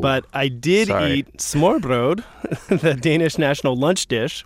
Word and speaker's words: smørrebrød: Open-faced sandwich is smørrebrød. – Smørrebrød. – Smørrebrød, yeah.